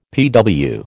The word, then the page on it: PW: American English